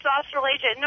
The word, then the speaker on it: Australasia: the Australasia. Did I say that right? – Yes, it's Australasia.